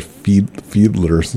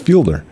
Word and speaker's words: Fielder: Is it Fielder or Fiedler's? It's Fielder.